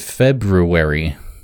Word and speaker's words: February: It's going to be a really good February with that extra R in there I forget to pronounce.